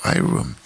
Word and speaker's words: E-Room: Bueno, se escribe así, E-Room, si fuera en inglés a lo mejor habría que pronunciarlo, I-Room.